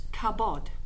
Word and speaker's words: kabod: The Hebrew word for glory is pronounced kabod, and so the loss of glory is ikabod, adding I to create the opposite to whatever word follows.